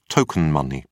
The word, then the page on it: token money: British English